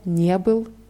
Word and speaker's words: не был: Ja, вчера я не был на работе. Hier wird die Betonung auf dieses Partikel ne verlegt.